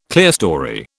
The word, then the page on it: clerestory: British English